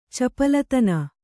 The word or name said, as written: ಚಪಲತನ